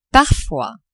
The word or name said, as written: parfois